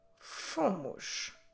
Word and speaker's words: fomos: Nós fomos. Como se escreve fomos? F-O-M-O-S.